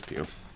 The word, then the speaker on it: Pugh: Yes, it was not Florence Pug. – Why do you say her name like that? – It's Pew.